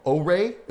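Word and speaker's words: Ouré: Called O-Ray, but it's spelled O-U-R-E. But thankfully, they said it's pronounced O-Ray.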